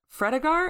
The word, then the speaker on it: Fredegar: And then we see a familiar, unfamiliar face in Fredegar.